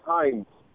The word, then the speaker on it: Heinz: It is Heinz. – Heinz? – Yes, sir. – Like the ketchup? How do I spell it? – Yes, that's exactly like that, sir.